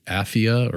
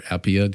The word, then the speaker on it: Apphia: It's actually written to Philemon, to Apphia or Appia, depending on how you pronounce the name.